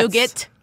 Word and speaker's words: nougat: A nougat. – Yeah, but nougat. – But the Milky Way has all of the planets. – Nougat almost rhymes with get it.